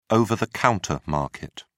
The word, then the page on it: over-the-counter market: British English